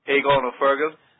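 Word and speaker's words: Aogán Ó Fearghail: So, what is it? It's Éagon? – Éagon O'Fergill. Yeah, oh, Éagon. – I mean, I'm not 100% sure either, but that's how I do it.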